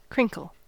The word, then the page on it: crinkle: American English